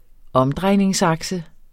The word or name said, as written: omdrejningsakse